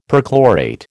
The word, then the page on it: perchlorate: American English